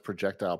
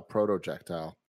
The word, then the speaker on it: projectile: I always thought you pronounced projectile, protojectile, until Kevin made fun of me.